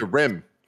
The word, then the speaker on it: Skyrim: I think the emphasis is on the rim part. – Okay. – Skyrim.